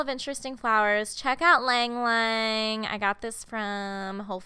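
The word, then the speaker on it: ylang ylang: It's called Lang Lang, but it's spelled Y-L-A-N-G, and then you repeat that.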